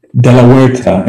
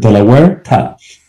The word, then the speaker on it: de la Huerta: De La Huerta. In Spanish, the H is silent, so go to state Delaware and throw a ta after it. De La Huerta.